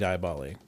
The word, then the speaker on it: Diaboli: Sigillum Diaboli? – Yeah. – Diaboli? – Yeah, Diaboli. It's definitely Diaboli. – Yeah. – Sigillum. Sigillum Diaboli.